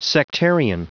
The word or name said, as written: sectarian